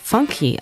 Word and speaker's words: Funk: Der brasilianische Funk, der auf Portugiesisch Funky ausgesprochen wird, entsteht in den 70er Jahren, als Funk aus den USA nach Brasilien schwappt.